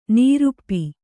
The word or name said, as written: ನೃಪ